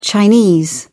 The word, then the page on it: chinese: British English